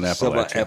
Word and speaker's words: Appalachia: she said you're going to pronounce it like throwing Appalachia. – Right. If you pronounce it wrong, somebody's going to throw an Appalachia. – Appalachia. That's it.